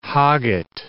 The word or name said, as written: hogget